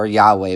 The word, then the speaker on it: Yahweh: God tells Moses that he was not known to Abraham, Isaac, and Jacob by his name Jehovah, or Yahweh, we would say. It's a better way of pronouncing it.